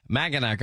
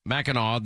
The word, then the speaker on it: Mackinac: Abdul El Sayed saying he hates, you know, this one island is called Mackinac, I believe is how you pronounce it, in Michigan, that apparently is Mackinac, that everybody loves.